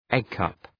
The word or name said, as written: egg-cup